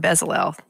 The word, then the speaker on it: Bezalel: And not only that, also nice job pronouncing a Aholiab and Bezalel.